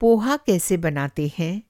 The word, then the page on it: पोहा: Poha